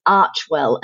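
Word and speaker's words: Archewell: So the name is of their new foundation and charitable venture is Archwell, and that's spelled A-R-C-H-E and then Well, W-E-L-L. Now, obviously, it sounds a lot like Archie, and there's a reason for that. And the reason is that Arch, the A-R-C-H-E, is apparently a Greek word meaning source of action.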